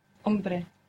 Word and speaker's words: hombre: For example, the word for man was, begins with a silent H. – Hombre.